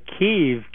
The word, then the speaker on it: Kyiv: Well, from what my understanding is that Kiev, K-Y-I-V, is sort of the Ukrainian pronunciation, and Kiev is K-I-E-V, is the Russian pronunciation. The same city, just pronounced different ways in two slightly different languages.